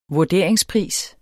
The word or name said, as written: vurderingspris